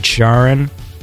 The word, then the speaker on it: Charon: So I'm going to say it, I guess, the way that the guy who coined the name, some dude called Christie, he pronounces it Charon. So I'm going to pronounce it Charon.